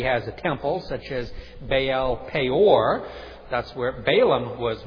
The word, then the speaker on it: Baal: Now, the other one we find out mostly in Scripture, and he occurs in these ancient tablets as well, is Baal, pronounced in Hebrew, Baal. So you may hear me pronounce it Baal or Baal, as we usually do in English.